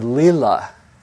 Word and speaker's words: lila: In fact, in Hinduism, there's a word, an actual word, that talks about the play of life, the word lila, sometimes it's spelled L-I-L-A or L-E-E-L-A, the play of consciousness that expresses itself in all these many forms.